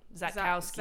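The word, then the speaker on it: Szatkowski: That S is silent, baby. Zatkowski.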